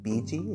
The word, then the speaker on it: Bigi: He would rather you call him, I think it is pronounced BG. It's spelled B-I-G-I.